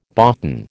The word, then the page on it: boughten: American English